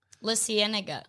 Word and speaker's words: La Cienega: Is that how you say it? La Cienega? – Well, I guess it is. – So. – La Cienega.